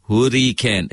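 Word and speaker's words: Huracán: In Italian, it's pronounced Hurrican.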